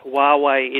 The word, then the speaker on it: Huawei: How do you say this? Huawei. – If I had to take a guess, Ditz, I'd say Huawei. – Oh, you've got inside info. Let's get our expert on, Trevor Long. Trev, all the way from San Jose. Good morning. – You've nailed it. Huawei it is.